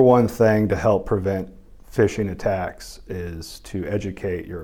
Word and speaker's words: phishing: Phishing is even though it's spelled with a PH, it is phishing for people's credentials is more or less what it's about.